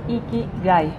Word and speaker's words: Ikigai: María Fernanda Cabrera de Ikigai. ¿Lo dije bien? – Sí.